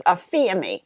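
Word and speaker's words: aphemi (ἀφίημι): So going back to the Greek word for forgiveness, the Greek word is actually pronounced aphemi.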